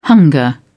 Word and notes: hunger: British English

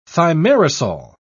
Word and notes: thimerosal: British English

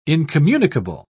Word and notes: incommunicable: British English

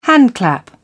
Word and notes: handclap: British English

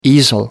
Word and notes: easel: American English

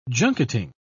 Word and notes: junketing: British English

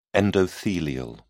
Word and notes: endothelial: British English

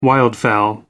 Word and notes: wildfowl: American English

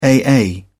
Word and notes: aa: British English

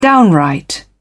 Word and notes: downright: British English

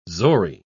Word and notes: zori: British English

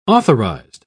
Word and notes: authorized: British English

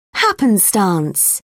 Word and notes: happenstance: British English

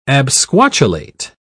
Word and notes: absquatulate: British English

